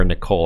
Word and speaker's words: Nicholl: Colin R. Nick. Cole. I'm, I'm, sorry, I butchered that name. It's Colin R. Nicholl, I guess. It's two L's, one one O.